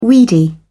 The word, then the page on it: weedy: British English